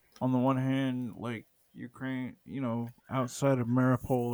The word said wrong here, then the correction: Mariupol: or however you say that. How how do you say the name of that city? Mariupol, Mariupol. It's yeah, Mariupol. – Well, you can just Mariupol. Mariupol. Mariupol, kind of like that.